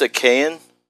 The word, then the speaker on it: Achaean: At the very end of the Trojan War, when the Greeks sacked the city of Troy, many of the Achaean... How do you pronounce that? – I think it's Achaean.